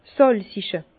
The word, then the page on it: 's sàlzige: Bas-Rhin; Herrlisheim